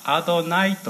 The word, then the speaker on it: アドナイ: ちなみに小文字の主というのはアドナイと発音し、これはご主人様という意味です。